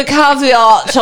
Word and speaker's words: caveat: I would like to have put a caveat on that. – I thought you had thrown in an extra syllable, but I guess you're right.